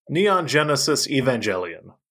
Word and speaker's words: Neon Genesis Evangelion: One time, 3-4 years ago, on Twitter, somebody asked Mr. Elon Musk if he liked, and I'm going to butcher the name because I always do, it's my favorite thing to do, Neon Evangelion Genesis. – Neon Genesis Evangelion.